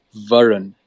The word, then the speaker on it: Varun: I think it's just a little bit more of an emphasis on the run. I guess Varun.